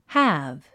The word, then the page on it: halve: hǽv; ハーブ